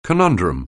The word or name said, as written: conundrum